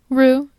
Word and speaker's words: Rieux: Dr. Rue, how do you say R I E U X? Is it Rue? – Rue, yeah.